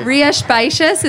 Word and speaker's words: Rías Baixas: Rías Baixas, it's spelled RIASBAIXAS.